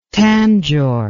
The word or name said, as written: tangor